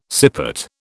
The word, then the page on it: sippet: British English